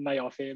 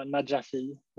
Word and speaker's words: Najafi: Eh, Najafi, ja, eller? De, de, de flesta säger Najafi, men egentligen ska det vara Najafi.